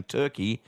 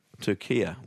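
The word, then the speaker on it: Türkiye: But in yesterday's Socceroos update, you kept saying Turkey and not – Turkia. – Turkia. What's doing? I'd never heard of Turkia, Ryan, is my honest answer. Yeah. Never, ever, ever heard of it pronounced that way.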